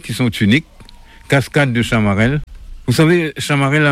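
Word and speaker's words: Chamarel: Tellement que Chamarelle a un nom prononcé, on doit dire Chamarelle.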